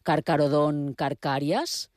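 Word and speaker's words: Carcharodon carcharias: Bé, si els noms de les espècies són en llatí, sempre he donat per fet que he de pronunciar la CH com cap, perquè jo sempre dic, per exemple, Carcarodon carcàries, i a vegades s'han sorprès perquè ho dic així. Això seria el correcte o no? – No, la pronunciació correcta és la pronunciació llatina.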